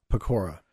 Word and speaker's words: Pecora: There's this fellow by the name of, I'm not even sure I'm going to pronounce this correctly, Joe, Ferdinand, is it Pecora? – Pecora, yep.